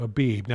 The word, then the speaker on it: Abib: Now, in most English translations of the Bible, it's going to be spelled A-B-I-B, like B as in boy. Now, phonetically in Hebrew, it's probably more accurate to have a V-I-V, like V as in Victor.